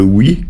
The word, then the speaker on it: oc: C'est la conjugaison du verbe « être » avec le « oui » qui se dit « o » en occitan et qui s'écrit « oc » si on veut.